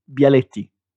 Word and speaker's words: Bialetti: In the US, the one that you pronounce better than me, the Bialetti. What did you call it? – Bialetti.